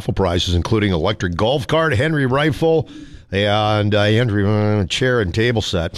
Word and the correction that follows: Adirondack: Adirondack, you mean? – Is that how you say it? – Yes. All right.